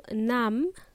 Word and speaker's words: numb: Uh, what this means, if you feel numb, uh, it means that you don't have any feeling whatsoever. Numb is spelled as N U M B and B is silent.